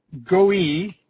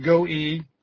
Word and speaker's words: goy: And in the scriptures, of course, the Testament word, the Hebrew word is goi, G-O-Y, pronounced goi, goim in the plural, and it's ethnos in the Greek.